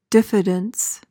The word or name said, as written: diffidence